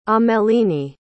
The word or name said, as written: ameline